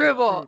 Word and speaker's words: Strubel: How do we pronounce that, Strubel? – Pronounce it Strubel, like apple. – Strubel.